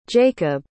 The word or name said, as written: jaycob